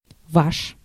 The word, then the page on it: yours: [jʊɹz]; /jɔː(ɹ)z/; /jɚz/; /jɝːz/; /jɔɹz/; /jɔːz/